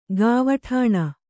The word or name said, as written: ಗಾವಠಾಣ